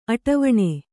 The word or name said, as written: ಅಟವಣೆ